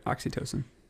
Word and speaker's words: oxytocin: Yeah, that's right, oxytocin.